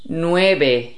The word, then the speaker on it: nueve: And then the V is pronounced as the B, nueve, nueve.